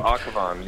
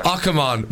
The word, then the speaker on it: Akhavan: Oh, Akhavan, yeah. – Akhavan. But we went on YouTube, and this is what we got. – Desiree Akhavan.